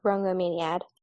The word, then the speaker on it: Rhongomyniad: It starts with an R, and I cannot pronounce it for the life of me. R-H-O-N-G-O-M-Y-N-I-A-D. – Yeah, it looks like it's pronounced Rongomaniad. So, yeah, she has a spear, too.